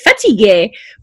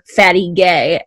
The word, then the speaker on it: fatigué: So she would say fatigué, which is how you say it. But obviously when you're little, you hear fatty gay.